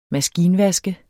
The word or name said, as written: maskinvask